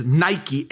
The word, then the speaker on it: Nike: See, that Greek word for more than conquerors is hupernikao, a word that starts off with the word that we would spell it as Nike, N-I-K-E. That's a Greek word, kind of sounds like footwear.